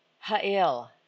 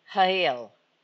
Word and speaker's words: hayil: I think you pronounce it ha'il, ha'il, H-A-Y-I-L.